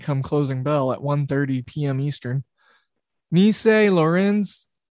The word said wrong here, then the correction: Lorient: or Lorient, I'm sorry. I always do the wrong pronunciation on them.